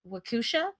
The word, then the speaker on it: Waukesha: It was May 31st, 2014 in Waukesha. I just watched a video on how to pronounce this. – Waukesha. – Okay, yeah, that's what I heard.